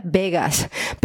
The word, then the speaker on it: Vaguess: Pues está el caso de Vegas, que he visto por ahí que se pronuncia Vegas, pero bueno, da igual, o sea, para que hagáis una idea de cómo se escribe, es V-A-G-U-E-S-S, ¿lo he dicho bien?